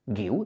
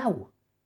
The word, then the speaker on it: Guiu: Si le añades una U, guiu, ¿de acuerdo? Si la joven promesa del Barça se llama Marc Guiu, es exactamente igual de fácil de pronunciar que en castellano.